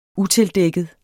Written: utildækket

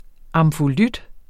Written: amfolyt

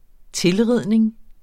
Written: tilridning

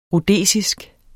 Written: rhodesisk

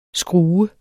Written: skrue

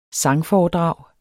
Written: sangforedrag

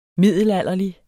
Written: middelalderlig

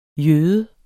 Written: jøde

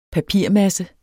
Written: papirmasse

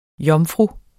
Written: Jomfruer

